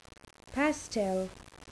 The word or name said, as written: pastel